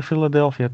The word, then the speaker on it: Philadelphia: Eh, tegen, zij moeten tegen Philadelphia, toch? – Yes. – Zeg ik dat goed, ja? Ja, ja, dat heb je goed gezegd.